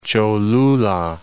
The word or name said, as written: Cholula